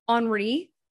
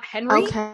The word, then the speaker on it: Henri: There was a hurricane Henri is apparently how you say it, not Henry. Henri.